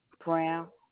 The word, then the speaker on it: Brown: I-N-D is in David, I-R-A, yes, ma'am. – Okay, and your last name? – Brown. – Can you spell that for me? – Brown, like the color brown.